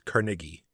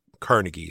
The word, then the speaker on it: Carnegie: The proper enunciation is Carnegie, but everyone else uses Carnegie, so that's why I just went with the common pronunciation.